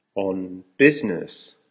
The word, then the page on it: on business: American English